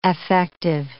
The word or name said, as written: affective